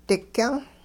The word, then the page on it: digyung: hupa